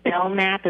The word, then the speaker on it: Belknap: It's actually, the K is silent, so it's pronounced Belknap as if the K isn't there, but I called it Belknap also when I first saw the name.